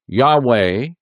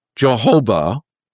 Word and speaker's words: Yahweh: The sacred names that they say that should be used comes from the Hebrew YHVH or YHWH, which is pronounced variously as Yahweh, Yahweh, Jehovah, and a shortened version, Jah.